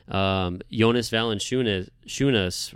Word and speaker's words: Jonas Valanciunas: When you match him up against a Jokic and Embiid, Jonas Valanciunas, right? Did I say that right? – You did.